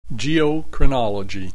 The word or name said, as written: geochronology